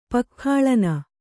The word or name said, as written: ಪಕ್ಖಾಳನ